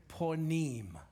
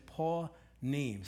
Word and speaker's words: panim: It's the word ponim, P-A-N-I-M, but we pronounce it ponim.